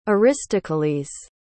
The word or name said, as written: aristokles